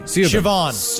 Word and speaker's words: Siobhan: Bunny, Sibohan Thompson, it's S-I-O-B-H-A-N. – Siobhan. That's Siobhan. – Is that Siobhan? – No, it's Siobhan, yeah.